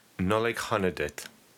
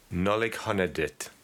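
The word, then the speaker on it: Nollaig Shona Duit: Nolig Honadit. – Can you repeat that? – Nolig Honadit. – Nolig Honadit.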